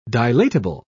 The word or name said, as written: dilatable